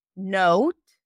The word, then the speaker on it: note: I'm not pronouncing it as want, it's won't. So imagine like there is a W at the end. Note, not not, note, comma, photo, stereo.